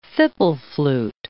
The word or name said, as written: fipple flute